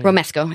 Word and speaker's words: Romesco: And that's just so crazy, and that's awesome, delicious, you know, Mexican food, as opposed to, say, like a Romanesco? – Romesco. – Yeah. Romesco. I say it wrong every time.